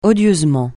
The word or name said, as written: odieusement